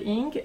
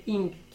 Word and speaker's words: Ng: Et la saison des feux de Céleste Inc. Je sais que ça se prononce inc parce que son compte Twitter s'appelle Pronounced Inc. C'est ça son pseudo sur Twitter, donc je sais que c'est Ing qui Elle est prévoyante.